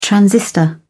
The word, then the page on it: transistor: British English